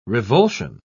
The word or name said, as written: revulsion